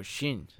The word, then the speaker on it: Shin: S-H-I-N. Shin? – Oh, Shin. – Yes. But you don't say the, but like they don't have the short I, so it's E. Shin.